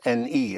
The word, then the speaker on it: ne: The N, which is spelled N-E as a separate word, but in speaking just a touch of an N, je ne, meaning I'm going to use a negation.